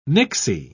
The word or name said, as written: nixie